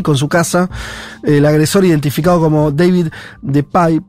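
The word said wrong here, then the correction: DePape: de Pape, se debe pronunciar, de 42, años, irrumpió la vivienda al grito de ¿Dónde está Nancy?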